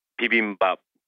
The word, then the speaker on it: bibimbap: bubble, which is, you know, Korean barbecue and, you know, bulgogi, and I'm going to mispronounce the egg dish, bibimbap. Is that how you say it? – you'd say bibimbap. – Bibimbap. – Yeah, I okay. think you're right there. That may be as good as you're going to get, but that's really good.